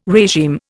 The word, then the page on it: regime: Indian